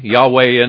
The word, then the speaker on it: Yahweh: Yahweh in the Hebrew is the way it's pronounced. Jehovah is the way it's pronounced in the English. It's what's called tetragrammation there with your four letters. J-H-V-H, the vowels have been supplied by the translators there.